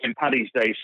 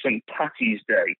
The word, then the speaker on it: St. Paddy's Day: is people calling St. Paddy's Day St. Patty's Day. – Yeah. – It's not the right way to say it.